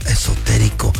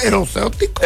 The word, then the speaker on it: esotérico: Ahorita vamos a regresar con experto esotérico José Isaías. Te conviene quedarte para que te des color de lo que pasa en el mundo mental. Y en el mundo esotérico. ¿Cómo se dice? Erosótico.